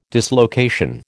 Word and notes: dislocation: American English

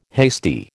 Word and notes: hasty: American English

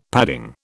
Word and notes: padding: British English

